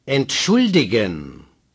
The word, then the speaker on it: Entschuldigen: Strong stress on the U. Say. – Entschuldigen.